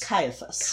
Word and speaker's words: Caiaphas: Is it Caiaphas? – Caiaphas.